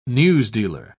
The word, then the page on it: newsdealer: British English